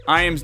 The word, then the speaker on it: Iams: Iams is spelled just like the dog food. Yes, it is. Iams dog food.